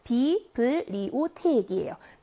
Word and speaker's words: Bibliothèque: 중간에 들어가 있는 알파벳 B가 자음 앞에 있긴 하잖아요. 여기 B 발음이 아니고 P 발음이 아니라 B로 발음합니다. 그래서 Bibliothèque이에요.